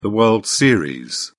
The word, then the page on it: world series: British English